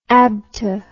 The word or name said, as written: ABTA